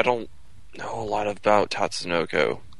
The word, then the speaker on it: Tatsunoko: And if you look like at a show like C, which I particularly liked, that's done by Tatsukuno. That's, I'm trying to remember if that's the right pronunciation for that Tatsunoko.